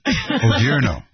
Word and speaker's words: Odierno: Now, this is Lieutenant General Ray Adorno. I'm having more. Why am I having so much trouble with pronunciation? You know, here's a confession. I know you're never supposed to confess publicly, but I have always had trouble with pronouncing really? – Names. Yeah. – Oh, I know that. I've been correcting you for 10 years. – Odierno. Odierno.